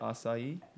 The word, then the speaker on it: acai: Acai? – Yeah.